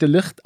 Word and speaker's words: De Ligt: Ich glaube, das ist Holländer. Und ich glaube, der wird De Ligt ausgesprochen.